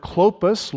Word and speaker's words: Clopas: The word Clopas, long O, the omega used there, different name, different derivation.